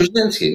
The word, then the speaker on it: Krasinski: Because you were at Emily Blunt and John, I always say his name wrong, Krasinski, right? – Krasinski, no, you said it Krasinski's, wrong.